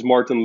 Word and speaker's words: Martijn: So my my full name is Martin Lancy.